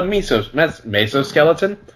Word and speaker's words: mesoskeleton: Instead, they possess a series of stiffening fibers embedded in the skin, which researchers have dubbed the mesoskeleton. Mesoskeleton. That's the pronunciation.